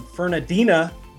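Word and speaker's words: Fernandina: You are the pastor at Amelia Baptist Church in Fernadina Beach, Florida. See, I pronounced it perfectly. Did you notice that? – You did, your first time too. There was no correction.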